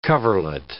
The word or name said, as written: coverlet